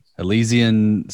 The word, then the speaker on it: Elysian: Is that how you pronounce it? – Elysian Space Dust IPA? – Yes. – That's how I would say it.